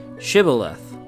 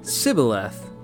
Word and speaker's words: Shibboleth: If he said no, they would tell him, then say, "Shibboleth." But he would say, "Sibboleth," because he couldn't pronounce it correctly.